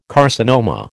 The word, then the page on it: carcinoma: American English